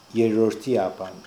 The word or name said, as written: Erordiapan